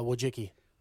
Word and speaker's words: Wojcicki: By the way, how do I say her last name? – Wojcicki. – Wojcicki. Oh, wow. Yeah. I would not think that from the spelling.